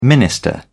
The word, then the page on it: minister: British English